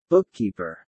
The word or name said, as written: bookkeeper